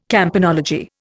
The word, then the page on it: campanology: Indian